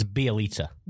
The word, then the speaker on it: Bjelica: Do you pronounce it Bjelica? – It's Bjelica. – Bjelica. – Yeah. – Bjelica. Okay, cool. – That's how I've heard it said.